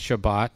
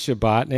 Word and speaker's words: Shabbat: And I was thinking, because the last Shabbat that I had, which is the way the Israelis pronounce it, the last one that I had, I went for a drive with my friends.